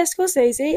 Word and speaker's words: Scorsese: Yeah, and I don't really know if I'm pronouncing it correctly. Scorsese? – Yeah, Scorsese. Yeah.